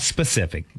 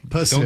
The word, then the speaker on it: Pacific: Not specific. – Pacific.